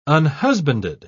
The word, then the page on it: unhusbanded: British English